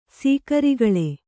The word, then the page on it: ಸೀಕರಿಗೊಳು: sīkarigoḷu